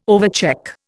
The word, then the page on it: overcheck: Indian